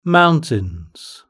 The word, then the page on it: mountains: British English